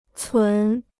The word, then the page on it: 存: cún